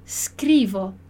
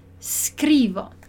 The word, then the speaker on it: scrivo: Então, poesia a gente vai pronunciar da mesma forma, mas o scrivo, a gente tem um S mudo aqui, S mudo. E aí, toda vez que a gente tiver um S mudo, a gente tem que prestar atenção para não pronunciar um I ou um E antes, porque ele não tem ali, não tá. Eu começo a falar a sílaba só com S, né?